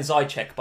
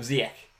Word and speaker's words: Ziyech: You've got to stop saying Zajcek, by the way. – What is it? – A lot of YouTube comments last week about your pronunciation of Ziyech.